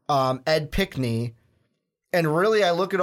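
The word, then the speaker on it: Pinckney: You got Ed Pickney, I believe is how you say it. – Correct.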